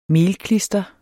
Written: melklister

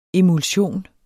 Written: emulsion

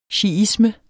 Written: shiisme

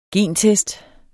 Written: gentest

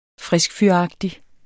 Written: FRISKFYRAGTIG

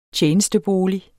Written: TJENESTEBOLIG

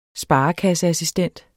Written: sparekasseassistent

